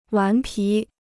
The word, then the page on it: 顽皮: wán pí